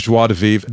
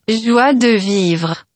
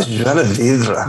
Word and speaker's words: Joie de Vivre: This is actually Google Translate just to show me how poor my... – Joie de Vivre. – Oh, Joie de Vivre. – That's how poor my pronunciation is.